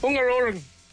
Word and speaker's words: Hungaroring: Was it A, Silverstone, B, Suzuka, or C, Hungaroring? How do I say it? – Hungaroring.